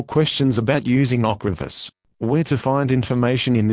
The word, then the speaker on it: Ocrevus: This is the consumer medicine information for Ocrevus, pronounced Ocrevus.